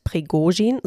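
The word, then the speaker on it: Prigozhin (Пригожин): Es besteht aber auch Rivalität und Prigozhin, so übrigens die richtige Aussprache, kritisiert den russischen Verteidigungsminister Shoigu immer wieder.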